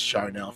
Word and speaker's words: Charneil: It's Charnel for CT.